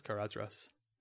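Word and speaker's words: Caradhras: It's actually pronounced Carathras. But... – I think it's pronounced differently say it differently in the movie. It's got a D in the book.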